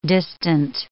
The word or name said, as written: distant